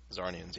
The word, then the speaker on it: Zarnians: Do you pronounce the Z? Just Zarnians? – Yeah. I thought it was. That's how Zarnians. I always said it. – Zarnians, yeah.